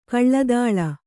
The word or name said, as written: ಕಳ್ಳದಾಳ